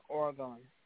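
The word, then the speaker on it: Orgone: So when you see their name, how it's spelled, it's O-R-G-O-N-E, but over the O is like a sim, like two dots. So it's like it's a foreign name, but how you would say it is Oregon. Not Oregon like the state.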